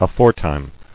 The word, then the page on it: Aforetime: a·fore·time; A*fore"time`